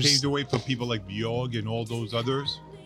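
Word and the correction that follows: Björk: How do you pronounce her name? – Bjorg. – Bjork. – Bjork. – Bjork? – Not Bjorg.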